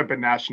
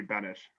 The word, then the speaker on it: Benesh: I respond better to Benesh now than I do Benesh. Like, I'll snap my head at Benesh.